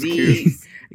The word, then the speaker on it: Combs: Coombs, Combs, Coombs. – Yeah, I was confused about that before.